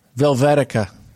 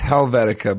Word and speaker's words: Helvetica: Velvetica. – Thou shalt not kill. – Yeah. – I think it's Helvetica. But I said it wrong on purpose.